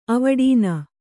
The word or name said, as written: ಅವಡೀನ